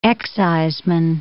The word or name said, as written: exciseman